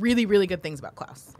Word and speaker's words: Klaus: Really quick, I think that's supposed to be Klaus. – What's that? – It is Klaus. Yeah. You're right. Yeah, not yeah. Klaus. – Just pronunciation.